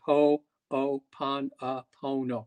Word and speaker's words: Ho'oponopono: It is actually H-O apostrophe O-P-O-N-O-P-O-N-O. – Yeah, just like it sounds. Ho'oponopono.